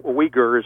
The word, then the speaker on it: Uyghur: it's U-I-G-H-U-R, isn't that the way it's usually spelled in English, Jim? – Yeah. – Uyghur is how it's pronounced, but there was a Uyghur woman testifying, I believe it was before a House committee, had a translator there.